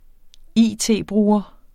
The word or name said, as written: it-bruger